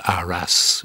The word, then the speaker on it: Arras: Rat in French sounds like Arras. That might be the origin of it, although they pronounce, oddly in French, they pronounce the S in Arras.